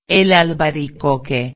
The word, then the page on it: el albaricoque: espanhol